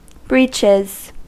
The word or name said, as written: breeches